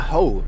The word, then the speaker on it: Hoh: Sunday, we just decided to drive out to the rainforest, the Ho Rainforest, H-O-H.